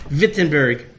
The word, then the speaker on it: Wittenberg: In German, a W is pronounced with a V. V. Wittenberg.